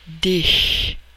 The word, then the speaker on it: dich: The umlaut, two dots over the A in H-A-N-D-E, is pronounced E, Hände. The CH after several words, like dich, is pronounced like the H in huge, huge, dich.